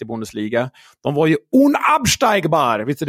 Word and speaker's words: unabsteigbar: Unabsteigbar, precis. Det är helt rätt.